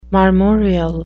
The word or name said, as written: marmoreal